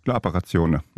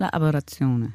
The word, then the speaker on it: La Apparazione: Jeg skal først lige høre, udtaler jeg værknavnet rigtigt, La Apparazione? – Ja. – Altså sådan, hvordan vil du sige det? – La Apparazione. – Ja. La Apparazione, ja.